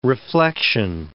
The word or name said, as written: reflection